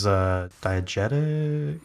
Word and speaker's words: diegetic: Yeah, I can see that because a lot of the movie is diegetic. How do you say it? – Diegetic.